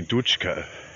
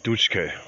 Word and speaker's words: Dutschke: And I do take issue with the guy's pronunciation. It's Rudi Dutschke. Dutschke.